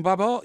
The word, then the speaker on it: Babar: Storm Babar? Yeah, pronounced like Barber, as in the Barber of Seville.